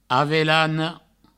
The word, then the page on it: avelana: avelano; Provençal